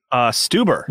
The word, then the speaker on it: Stuber: Stuber. – Wow. Hell yeah. – Yeah. Yeah. – I remember because it rhymes with Uber.